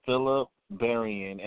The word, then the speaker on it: Phillip Berrian: I'm Mr. Phillip Berrian, and I like what you said earlier, Phillip B, and B stands for bold, beautiful, and black is beautiful.